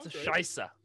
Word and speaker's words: Scheiße: Well, it's pronounced Scheisse.